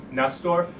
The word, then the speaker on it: Nussdorf: N-U-S-S. – Say it again. – Nestor. – Nestor. – Yes. N-U-S-S-D-O-R-F.